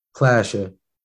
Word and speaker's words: Plasha: Plasha.